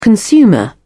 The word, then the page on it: consumer: British English